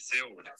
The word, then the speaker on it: Seoul: På svenska ska du uttala Seoul. OS i Seoul.